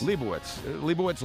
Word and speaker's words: Leibovitz: You know, whenever it comes to Annie Leibovitz, and is it Leibovitz? – Leibovitz. Leibovitz. – You pronounce it Leibovitz. – I guess the German pronunciation would be Witz, right? – I always called her Annie Gold.